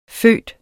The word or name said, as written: føde